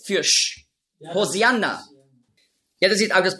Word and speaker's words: Hosianna: Hosianna ist einfach die griechische Aussprache von Hoshi'anna. Hosianna, das ist die griechische Aussprache.